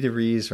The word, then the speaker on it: DeVries: Yes, that's the very first appearance of Vicky DeVries rhymes with cheese.